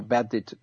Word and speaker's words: Beddit: Because you start to mouth breathe, and then you can see even on your like a bedded application that your breathing frequency is going up.